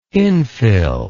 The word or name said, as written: infill